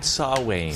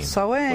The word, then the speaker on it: Samhain: Do you spell it Samhain? – Yes. That's exactly how you spell it. – But you say it Samhain. – Samhain. – What's with that? – I didn't make it up. I just follow the rules.